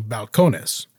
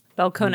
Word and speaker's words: Balcones: So the rule is, as it was explained to me, if the word ends in a vowel, an N or an S, the invisible accent is on the second to last syllable. So Balcones. – Balcones. Yes. Yeah, so Balcones. Balcones is how, and like I just whited that up, guys. But that's how, I watched two videos that both were like from the brand, and Balcones was how they pronounced it. So we think it's Balcones.